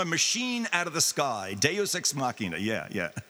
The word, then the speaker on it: Deus Ex Machina: I'm an English major and political science, but I think there's a literary character, and it's called, the Deus Ex Machina? Am I mispronouncing that? – Deus Ex Machina, yeah.